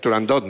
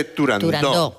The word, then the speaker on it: Turandot: Turandot no, se dice Turandot, que es... – Como lo pronunciaba, Puccini. Puccini y como lo pronunciaba Toscanini.